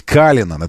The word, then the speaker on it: Cullinan: И он именно так настаивает в компании Rolls-Royce, нужно его называть. Cullinan.